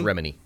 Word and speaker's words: Remini: It's Remini.